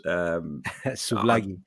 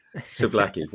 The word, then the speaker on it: souvlaki: Souvlaki. – Souvlaki.